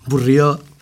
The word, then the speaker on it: Baudrillard: en Nicolás Burrió que se escribe Baudrillard. Por cierto, Baudrillard es Baudrillard.